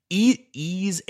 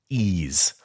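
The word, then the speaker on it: Ys: Ease 8. Lacrimosa of Dana. That's the series that's spelled Y-S, like big Y-S. Ease. Like the Joanna Newsom album.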